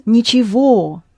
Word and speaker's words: ничего: It is ничего. Yes. Ничего. Ничего. Where г is pronounced as v.